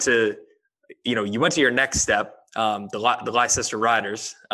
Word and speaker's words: Leicester: Yeah, pronounced Leicester.